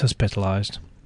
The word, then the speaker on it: hospitalised: 13-year-old Ryanell finds he has to step up and be a man when his mother, Lisa, has a breakdown and is hospitalised, however you pronounce it. – Hospitalised. Yeah, as in hospital, not a hospital. Oh, I'm going to the hospital.